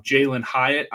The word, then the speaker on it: Jalin Hyatt: And then if you go down a little bit, you also got the rookie um, Jalin Hyatt, I believe is how you pronounce it, yep.